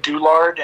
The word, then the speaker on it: Douillard: My name is uh, Justin Douillard, and the name is uh, the last name is spelled a lot more complicated than it looks.